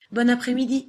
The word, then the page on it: bonne apres-midi: French